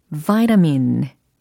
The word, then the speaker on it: vitamin: V-I-T-A-M-I-N 이겁니다. 비타민일까요? 아, 여러 가지 의견이 나오는데 vitamin, vitamin, vitamin 이렇게 발음해 주시면 완벽합니다. vitamin 이렇게 소리로 내주셔도 괜찮고요. vitamin 이렇게 예, 하셔도 좋습니다.